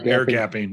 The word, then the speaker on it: air gapping: Um, is uh, Hastings is asking Is ear gaping? Am I pronouncing it right? Ear gaping? – Uh, um, air gapping.